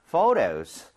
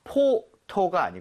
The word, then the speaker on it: photo: 그리고 참고로 ph는 f와 성질이 비슷하기 때문에 발음할 때 포토가 아니고요. Photo 이렇게 부드럽게 해주셔야 돼요.